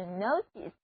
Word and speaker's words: notice: 那这个注意在英语中就是 notice， notice， N-O-T-I-C-E，字母 I 读作短的 E。你们看，单词的前半部分是词根 N-O-T 表示知道的，后面的 ICE 是一个名词后缀，合在一起提醒你让你知道，不就是通知或者注意吗？